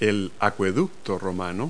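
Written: Roman aqueduct

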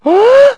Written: huh

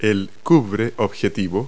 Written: lenshood

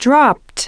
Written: dropped

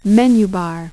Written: menu bar

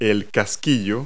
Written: socket

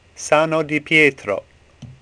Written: Sano

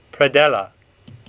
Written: Predella

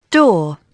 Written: door

